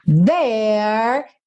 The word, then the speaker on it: There: There, TH, sempre linguinha, the, nos dentes da frente aqui, tá? Os dentes de cima, the. There, a letra E do final é pintada de cinza, então você não vai pronunciar. There is just one thing I need.